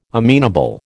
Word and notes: amenable: American English

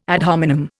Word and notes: ad hominem: Indian

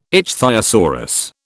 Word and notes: ichthyosaurus: British English

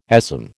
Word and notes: ASM: American English

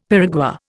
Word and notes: piragua: Indian

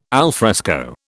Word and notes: alfresco: British English

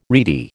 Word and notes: reedy: American English

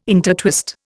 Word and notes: intertwist: Indian

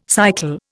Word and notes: cycle: Indian

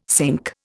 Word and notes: cinque: Indian

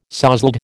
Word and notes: sozzled: American English

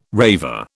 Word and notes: raver: British English

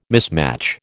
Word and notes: mismatch: American English

